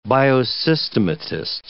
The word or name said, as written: biosystematist